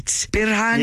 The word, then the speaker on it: Birhane: Birhane? – Yeah, that's...